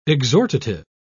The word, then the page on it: exhortative: British English